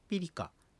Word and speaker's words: ピリカ: これは前の母音を弱く響かせた発音をしたり、そういった表記をしますが、まれにある、いる、うる、える、おると発音されることもあります。例えば、ナナという意味のアラワン、良いとかっていう意味のピリカというふうに、こういうふうに R は発音します。